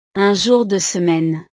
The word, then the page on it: un jour de semaine: frances